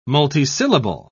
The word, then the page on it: multisyllable: British English